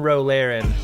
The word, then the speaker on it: Ro Laren: And with one Ro Laren, as I guess as Picard pronounces it, Ro Laren.